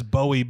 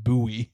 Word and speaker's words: Bowie: A while back, I went on a Tinder date at the most romantic of locations, the Olive Garden, and this is Bowie, but apparently it's pronounced Bowie, Maryland.